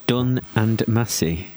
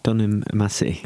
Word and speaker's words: Dunham Massey: Dun and Massey. – Dunham Massey. – Dunham. – Don't pronounce the H. – Dunham Massey. – Correct.